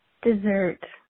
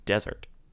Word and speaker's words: Dessert: That's not how it's pronounced. If you read the People magazine story about Nikki and Brie Bella revealing the special meanings behind each of their sons' names, you would know that Buddy's middle name is pronounced desert. Desert. – But it's spelled dessert.